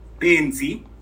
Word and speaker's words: benzi: Yeah, in Shona, you could say Benzi. – Penzi. – Yeah, like B-E-N-Z-I.